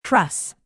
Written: truss

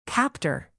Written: captor

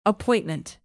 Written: appointment

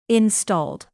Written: installed

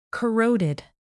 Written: corroded

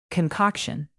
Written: concoction

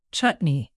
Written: chutney

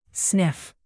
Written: sniff

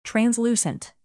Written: translucent

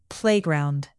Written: playground

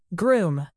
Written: groom

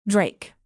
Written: drake

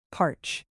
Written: parch

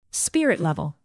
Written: spirit level

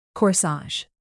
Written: corsage

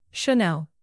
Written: chanel

